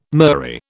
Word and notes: murrey: British English

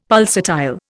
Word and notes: pulsatile: Indian

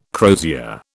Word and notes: crosier: British English

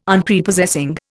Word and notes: Unprepossessing: Indian

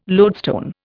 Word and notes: loadstone: Indian

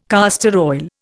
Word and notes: castor oil: Indian

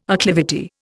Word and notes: acclivity: Indian

